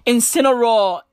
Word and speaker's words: Incineroar: It's spelled I-N-C-I-N-E-R-O-A-R. Incineroar.